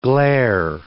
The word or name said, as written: glare